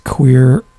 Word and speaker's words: Kwir: It's Queer, and it's spelled K-W-I-R.